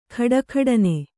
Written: ಖಡಖಡನೆ

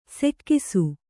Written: ಸೆಕ್ಕಿಸು

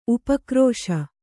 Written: ಉಪಕ್ರೋಶ

